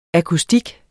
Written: akustik

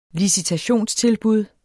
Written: licitationstilbud

